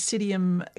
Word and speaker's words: Psidium: The botanical name is Psidium catalianum. – Oh, goodness, yes. – Starting with a P, so it's a silent P.